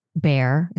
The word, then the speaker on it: Bayer: And the company was Bayer, is how we pronounce it here. I know it's pronounced different ways in different places.